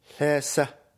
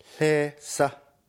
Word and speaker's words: Lhasa: Lhasa. Listen and repeat. Lhasa. Lhasa. Lhasa.